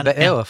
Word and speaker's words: Beowulf: Beowulf? – Yeah, Beowulf.